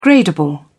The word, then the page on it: gradable: British English